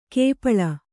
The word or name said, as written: ಕಪಾಳ